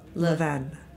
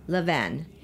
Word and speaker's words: Levain: Levain. – Levain.